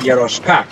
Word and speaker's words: Jaroska: That's so funny how you pronounce my name. I love it. – So, Devidas Jaroska. That's how you pronounce it.